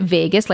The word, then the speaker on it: vagus: So it's spelled V as in Victor, A-G-U-S, but you pronounce it Vegas, like Las Vegas.